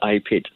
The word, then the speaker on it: IPID: So IPID stands for International Payments Identity, and we pronounce it as IPID.